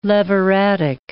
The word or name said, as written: leviratic